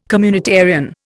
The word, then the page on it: communitarian: Indian